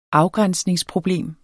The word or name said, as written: afgrænsningsproblem